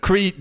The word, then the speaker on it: Crete: Wait, did he say crate or did he say Crete? – He said Crete. – Okay. – That was me mispronouncing it. I'm the idiot.